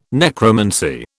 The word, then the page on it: necromancy: British English